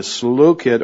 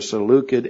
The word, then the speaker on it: Seleucid: I was listening to a podcast this week about this very period, and the guy was talking about, he was using a hard C for Seleucid Empire.